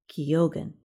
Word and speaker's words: Keoghan: The last name of Irish actor Barry Keoghan, who starred in Saltburn, is pronounced Keoghan.